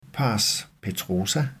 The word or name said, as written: pars petrosa